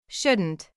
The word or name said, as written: shouldn't